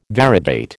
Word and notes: variegate: American English

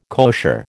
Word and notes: kosher: American English

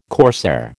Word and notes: corsair: American English

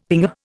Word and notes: pinger: Indian

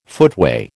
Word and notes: footway: American English

